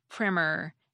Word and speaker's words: primer: Primer with a short I and meaning an introductory book is the older word, going all the way back to the 1300s.